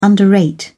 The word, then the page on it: underrate: British English